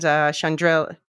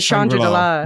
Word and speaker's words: Chandrila: Yeah, it seems to be the general consensus that Cassian stuck in the mud is the least favorite plot line, and Chandrila, yeah. – Yeah. – They were pronouncing it Chandrila, which is something. – Yeah, but no, it's Chandrila.